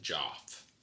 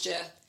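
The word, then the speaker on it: Geoff: I think they pronounce it Joff. – No, it's not. It's Jeff.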